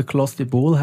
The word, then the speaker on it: Clostebol: Ich habe es gerade noch mal kurz nachgeschaut, also Clostebol heisst das. – Ich weiss nicht, dann habe ich es, glaube ich, richtig gesagt. – Genau, richtig gesagt.